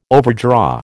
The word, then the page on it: overdraw: American English